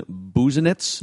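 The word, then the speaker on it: Busenitz: Ricky Nolasco and Alex Meyer for Hector Santiago, and I believe we're pronouncing it Alan Buznitz. – Yeah, I think Buznitz is correct.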